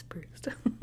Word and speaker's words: Proust: except that she mispronounces Proust and he has to correct her.